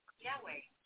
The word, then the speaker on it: Yahweh: Yahweh, named for the God of the Israelites, representing the biblical pronunciation of YHWH, the Hebrew name revealed to Moses in the book of Exodus.